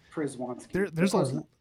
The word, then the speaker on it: Krzywanski: You missed the N. It's Krzywanski.